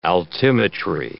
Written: altimetry

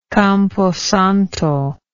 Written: camposanto

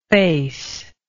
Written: faceable

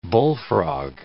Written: bullfrog

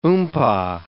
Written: oompah

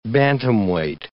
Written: bantamweight

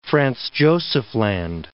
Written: Franz Josef Land

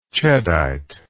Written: cheddite

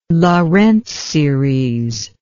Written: Laurent series

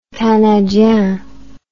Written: canadien

